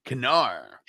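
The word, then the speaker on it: Knarre: He said you pronounce the K. Knarr.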